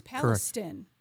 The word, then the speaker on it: Palestine: I think it is Palestine.